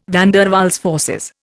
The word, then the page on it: van der Waals forces: Indian